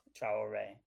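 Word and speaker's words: Traoré: Traore.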